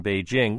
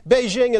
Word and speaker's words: Beijing: Spelled with a J, but pronounced with a Z. Beijing is rich in history, but still shrouded in mystery.